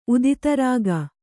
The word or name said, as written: ಉದಿತರಾಗ